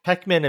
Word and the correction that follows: Peckham: and Brixton. I think that's Peckham.